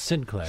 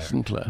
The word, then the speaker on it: St. Clair: It's even though it's spelled St. Clair, it's pronounced Sinclair. – Sinclair.